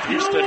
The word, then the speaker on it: Hiestand: Evan Heaston, spelled H-I-E-S-T-A-N-D, pronounced Heaston, 6'3", a junior, to forward.